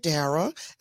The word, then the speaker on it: Darra: And I'm here with Dara, and I'm saying her name correctly.